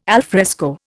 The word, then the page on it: alfresco: Indian